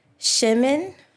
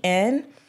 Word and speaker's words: shemen: And so as I took a deep dive into that scripture, God gave me the Hebrew word for anointing, and it means, it's pronounced Shemen, like Shehmen. Mm-hmm.